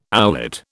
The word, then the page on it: owlet: British English